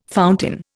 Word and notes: fountain: Indian

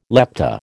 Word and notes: lepta: American English

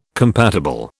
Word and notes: compatible: British English

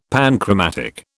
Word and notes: panchromatic: British English

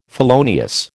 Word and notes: felonious: American English

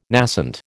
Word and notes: nascent: American English

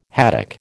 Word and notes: haddock: American English

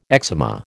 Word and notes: eczema: American English

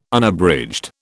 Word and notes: Unabridged: British English

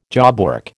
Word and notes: jobwork: American English

